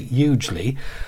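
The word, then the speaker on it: Ugley: It's U-G-L-E-Y, but everyone thinks it's funny because it's the Ugly Women's Institute. We did interview the woman in charge of it. She said, oh, no, we pronounce it hugely.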